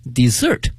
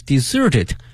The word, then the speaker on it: desert: 那么这个词的发音哈 desert 和呢另外一个词呢 desert 有的时候会弄混。 The village was deserted after the flood, 在洪水过后呢这个村子被呢遗弃掉了。但是呢这两个词的它的拼写呢一模一样的，但是呢发音不一样，名词 desert 沙漠，动词呢 desert 抛弃。那么还有一个词呢 d-e-s-s-e-r-t 多了一个 s, 它的发音是 desert, 它是指呢我们去点快餐的时候呢会有一些小的甜品，比如说像奶昔啊冰激凌啊等等，那么它通称为 dessert 啊，甜品。